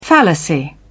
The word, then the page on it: fallacy: British English